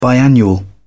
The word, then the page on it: biannual: British English